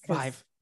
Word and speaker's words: Vive: Vive. – Rhymes with five.